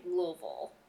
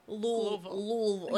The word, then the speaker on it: Louisville: If you're from Kentucky, you say Louisville. – Louisville. Like you have something stuck in your mouth. Louisville. – Exactly. – I still did better. I didn't say Louisville.